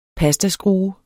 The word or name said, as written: pastaskrue